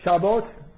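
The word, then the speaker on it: شبات (שבת): ده دقیقه وقت داریم ده دقیقه میگه شبات شبات یعنی لفظ یهودیشه تو قرآن مثلا سبت اومده سبت بهش میگن شیب الان انگلیسی ها اینجوری میگن حالا شبات تلفظ ابریش شبات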